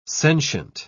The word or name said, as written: sentient